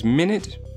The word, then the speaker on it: Minit: So if you want, it is Minute. It is spelled M-I-N-I-T.